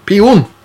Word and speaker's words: pion: Så ukens godbit er Oro, det er kjeks 19,90, og ukens blomst, den klarer jeg ikke å uttale. Det er pen... – Peon. – Peon. – Pion. – Pion. – Kanskje. Pion. Muligens.